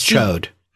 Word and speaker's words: Chaud: Now, I don't know if Chod is the correct pronunciation for this guy's name. – No, it's Chode. – Is that correct? – No. – C-H-A-U-D is the spelling.